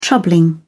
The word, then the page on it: troubling: British English